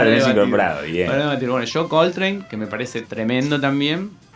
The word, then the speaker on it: Coltrane: Eh, número 3, John Coltrane. – También. – Eh, lo dije bien. – Muy bien, muy bien, lo pensaste.